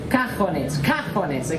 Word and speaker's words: cajones: Cajones, okay? The emphasis on the ca.